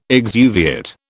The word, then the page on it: exuviate: British English